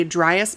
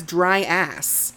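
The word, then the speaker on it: Dryas: So it actually would have been more accurate if they had used a dry-ass monkey. – A dry-ass monkey? – I was trying specifically not to pronounce it as dry-ass. – Sorry. – It's D-R-Y-A-S. Dry-as.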